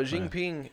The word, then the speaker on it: Xi Jinping: No, Xi Jinping, the president there in China. I'm probably butchering his name. – No, I think you said it right.